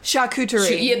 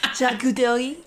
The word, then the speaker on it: charcuterie: But it starts with the letter C. It's almost like saying caricature. – Oh, charcuterie. – That. I see. I can't pronounce it. – No, that's okay. Charcuterie.